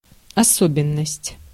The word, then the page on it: особенность: /ɐˈsobʲɪnːəsʲtʲ/